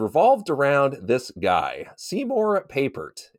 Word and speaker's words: Papert: No, and it took me like 2 years to figure out, okay, find somebody to pronounce it for me. It's Papert. – Papert, okay. – Yeah, yeah. – So I just kind of think of paper and then Papert.